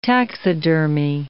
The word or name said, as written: taxidermy